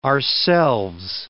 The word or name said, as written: ourselves